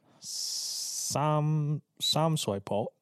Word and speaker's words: Sham Shui Po: Sham Shu Pu. – Wow. – Sham Shui Po. – Sham Shui Po. – Nope. – How do you pronounce it? – Sam Shui Po. Something like that.